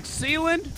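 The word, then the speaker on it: Seeland: The track Sealand, which rhymes with Leland, so it ain't all bad.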